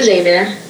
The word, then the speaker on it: Xavier: Did I say that right? – I went to Xavier, yes.